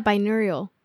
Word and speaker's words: binaural: Anyways, so in there it talks about, I think it talks about binaural beats. I don't know if that's how you say it. – Oh yeah, binaural.